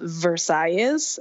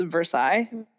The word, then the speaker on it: Versailles: Funny story, I was once with my family at a very important Cuban restaurant in Miami called Versailles, which yes. should actually be pronounced Versailles, but yes.